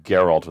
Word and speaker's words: Geralt: People pronounce it different ways, but in the show, the television show and the video game, they say Geralt with a hard G.